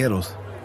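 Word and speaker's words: Héroes: Eros del Silencio. – Nee, Eros. – Ja, das H spricht man nicht.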